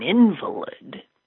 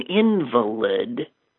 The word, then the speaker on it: invalid: The reason you don't want to be an invalid is being an invalid makes you, in many ways, invalid. An invalid is seen as invalid. Just change the stress onto the second syllable from the first.